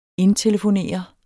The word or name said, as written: indtelefonere